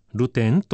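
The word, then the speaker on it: 流転: このような、困ったなあ、助かったなあ、また困ったなあ、助かったなあという繰り返しを、ルテンと呼べばいいのではないかと思います。流れ、転がると書いて、ルテンと読みます。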